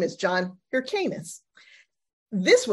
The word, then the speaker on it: Hyrcanus: And through that whole class, I pronounced John Hyrcanus's name wrong. It's Hyrcanus, not Hyrcanus.